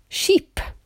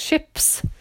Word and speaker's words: ships: So the building of ships, and it's the short I sound, not sheep, which is the animal, of course. So the answer is ships, and it's plural.